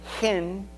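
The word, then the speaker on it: hen (חן): It comes from the Hebrew word, another Hebrew word. We would pronounce it hen.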